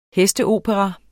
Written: hesteopera

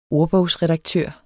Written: ordbogsredaktør

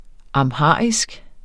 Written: amharisk

